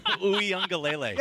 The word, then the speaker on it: Uiagalelei: You know, like Uiagalelei, is that how I say his name? – Uiagalelei. – Yes, him.